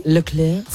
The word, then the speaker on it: Leclerc: Do you know the F1 driver, Charles Leclerc? I hope I'm saying that correctly. – Leclerc.